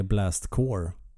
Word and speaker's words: Blast Corps: Nej, och jag tror faktiskt att man säger Blast Core. Uh... Det, det stavas med C-O-R-P-S. Mm. Men uttalas core.